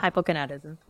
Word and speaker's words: hypogonadism: It is sometimes used as a medication to treat hypogonadism. Did I say that right? – Hypogonadism. But horribly. You said it correctly, slowly.